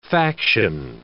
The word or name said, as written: faction